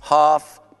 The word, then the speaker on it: half: It's half past. Non pronunciare L in half, metà.